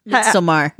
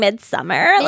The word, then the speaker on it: Midsommar: Also, for once and for all, how the heck do I pronounce it? – Midsommar. – Midsommar. Okay. – Midsommar. – Thank you. Because I keep saying midsummer. Like it's summer.